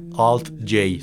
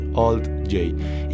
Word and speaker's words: Alt-J: Y la banda se pronuncia Alt-J. Si les enseñaron el abecedario en inglés, podrán pronunciar esta J. Se dice Alt-J. Y nos vamos con la canción Matilda.